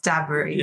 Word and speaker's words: Dabiri: Yeah, but that was the incorrect pronunciation. – It's Dabiri. – Yeah. – So it's actually like often people do their research and then they still say Dabiri because Everything they check says Dabiri.